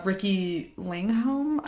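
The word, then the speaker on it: Riki Lindhome: But actress or comedian Ricky Lingholm, I don't know if I'm pronouncing her name right. – Oh, yes.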